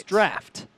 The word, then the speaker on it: draught: I learned that Guinness draught is not pronounced that way. – Right, it's draft.